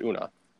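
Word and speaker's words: Oonagh: Her name was Una, but it was spelled O-O-G-N-A-H, or something like that. Like O-O-H-G-N-A-H. It was weird. I know it started with O-O and pronounced Una.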